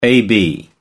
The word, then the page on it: ab: American English